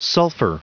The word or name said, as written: sulfur